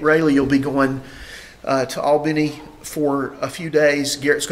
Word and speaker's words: Albany: which I think is properly pronounced Albany in Georgia.